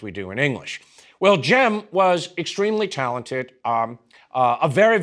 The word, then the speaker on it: Cem: That's spelled C-E-M, and in modern Turkish, the initial C is a J sound.